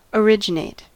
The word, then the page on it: originate: American English